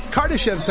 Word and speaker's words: Kardashev: The Kardashev scale? – Kardashev sounds good to me. It looks like it's Russian, so I think... – It is Russian. – That'd probably be the right pronunciation.